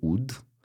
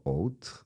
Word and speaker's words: oud: Ovde se govori ud, Uh, ja mislim da je pravilnije mnogo oud, zato što po srpskom pravopisu se vratim na pravopis.